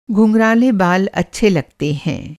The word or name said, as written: घुँघराला